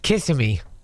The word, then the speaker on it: Kissimmee: How do you pronounce it, Kissimmee or Kissimmee? – I always thought it was pronounced Kissimmee.